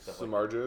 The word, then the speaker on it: Samardzija: So this is Chad here with TortureCast, here with starting pitcher Jeff Samardzija. Is that how you pronounce it? You always see the debates on Twitter. – Samardzija would be correct.